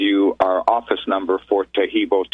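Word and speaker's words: Taheebo: And Tahibo is the only tricky part to spell it, is T-A-H-E-E-B like boy, O. And then the second word is T-T-E-A. And the third word is club, C-L-U-B.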